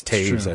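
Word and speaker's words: Toews: Too bad his name isn't actually pronounced like that. It's Taves, I think. – Yeah. – Yeah. – Loser. – Yes, in a way. You got T-O-E in your last name, and they don't pronounce it toe.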